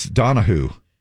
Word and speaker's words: Donahue: Now, before you think that we're saying this guy's name wrong, it's Donahue.